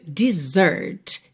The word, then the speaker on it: desert: Aqui nós temos o verbo to desert. Se escreve D-E-S-E-R-T. Alright? D-E-S-E-R-T. A sílaba tônica é a segunda, então desert, desert. E esse S tem som de Z, right?